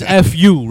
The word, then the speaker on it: Eff Yoo: My name is F.U., right? Yo, you don't understand the amount of people that mispronounce my name. – I'm sure. – F.Yo. – F.Yo. Right? Or fucking Eef. Eef you.